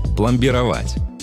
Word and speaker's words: пломбировать: Одно из них – пломбировать. Да, ударение в нем ставится именно так и никак иначе.